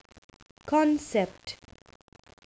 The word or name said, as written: concept